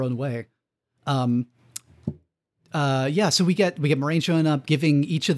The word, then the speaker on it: Moiraine: Officially, it's sort of pronounced the French way, but not quite. It's like Moiraine. And how interesting. – We'll find out it is actually pronounced the French way, but we're in merry like British Isles here in this section of the world in Emmons Field to River. So they go with Moiraine according to Jordan and the glossary.